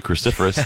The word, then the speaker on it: cruciferous: Cruciferous.